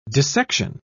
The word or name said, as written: dissection